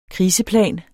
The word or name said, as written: kriseplan